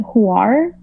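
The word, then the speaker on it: Hvar: I got to see, it's spelled H-B-A-R, but they pronounce it Huar, like a U.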